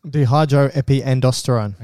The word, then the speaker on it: dehydroepiandrosterone: Dehydroepiandrosterone.